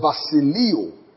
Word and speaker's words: basileuo: And that's spelled as basileo. B-A-S-I-L-E-U-O.